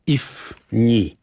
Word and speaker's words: Ifni: Gdyby patrzeć na mapę, to gdzieś tak pod Marokiem on był, a pisze się, pisało I, F, Ferdynand, N, Natalia, I, Irena. Ifni.